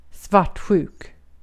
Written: svartsjuk

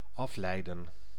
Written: differentiate

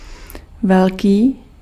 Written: big

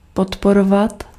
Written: soutenir

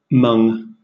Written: mung

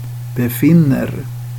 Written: befinner

